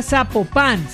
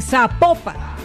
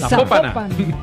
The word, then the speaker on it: Zapopan: Nos escriben los oyentes y que sapo, no se pronuncia Zapopan, sino que se pronuncia Zapopan. – Zapopan.